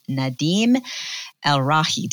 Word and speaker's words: Nadim El-Rahi: All right, you guys, I am excited to have Nadim Elrahi.